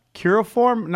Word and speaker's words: cuneiform: Cuneiform? How do you pronounce that? – Cuneiform. C-U-N-E. Iform. I-F-O-R-M. So cuneiform.